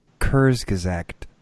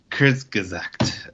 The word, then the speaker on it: Kurzgesagt: Kurzgesagt? – Kurzgesagt. I don't know. That sounds the most right. – The G is silent. Huh? Kurzgesagt. – Huh. – I'm just guessing. Okay.